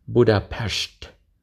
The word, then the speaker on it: Budapest: If you want to be fancy like a radio announcer, the proper pronunciation is Budapest.